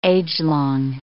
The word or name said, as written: agelong